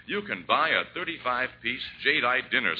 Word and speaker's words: jadeite: Remember the name, jadeite. That's spelled J-A-D-E-I-T-E. Jadeite.